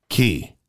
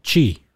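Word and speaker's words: Qi: Key. – That's how that's pronounced? – Yes. – How's it spelled? – What have you been saying? – Chi. – No. Only if it was Spanish. Or not. Chinese.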